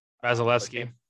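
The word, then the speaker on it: Vasilevskiy: Vasilevsky.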